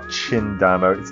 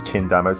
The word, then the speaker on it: Chindamo: It's not Chindamo. It's pronounced Kendama, C-H-I-N-D-A-M-O.